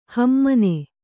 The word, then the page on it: ಹಿಮಾನಿ: himāni